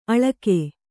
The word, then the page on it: ಅಳ್ಗು: Kannada